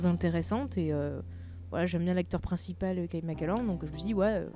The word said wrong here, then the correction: MacLachlan: Comment tu prononces le nom de l'acteur? – Moi, je dis Kyle McAllen. McLand, je ne sais pas si j'ai la prononciation parfaite, nom, c'est Kyle MacLachlan. Moi, je prononce ça comme ça.